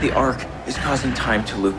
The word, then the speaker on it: ARQ: It is spelled A-R-Q, but it is pronounced ARK.